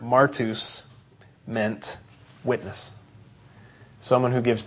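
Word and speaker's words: martus (μάρτυς): It's the Greek word that's pronounced martus, and so we get our word martyr directly from this word martus.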